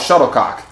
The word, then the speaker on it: shuttlecock: It's called a shuttlecock. – You got to put emphasis on the last syllable, don't you? – Shuttlecock.